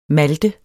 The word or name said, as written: malte